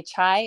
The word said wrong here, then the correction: chia: Oh, chia seeds. – Chia seeds. Okay.